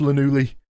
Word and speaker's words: Llanelli: Actually, it's, I think you'll find it's pronounced Llanelli.